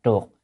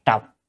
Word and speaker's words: trược: Nè, chữ đó đọc là trược, có khi là có người không biết dịch là trọc, thì theo từ điển là trọc nhưng thực tế là trược.